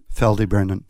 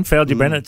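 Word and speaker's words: Ffald-y-Brenin: And how do you pronounce it? For Faldy Brennan? – Faldy Brennan. – That's the one, Faldy Brennan. It's like a Welsh word.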